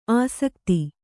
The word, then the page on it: ಆಸಕ್ತಿ: āsakti